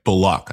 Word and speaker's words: Bullock: Bullock, I said it right. – There you go.